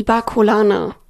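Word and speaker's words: Barcolana: Ihre, wie spricht man das jetzt richtig aus? – Die Bacolana. – Mein Gehirn will da immer Barcelona draußen machen.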